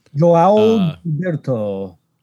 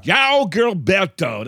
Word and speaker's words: João Gilberto: João Gilberto. – Yeah. – João Gilberto. That's absolutely right. That's how you pronounce it, too.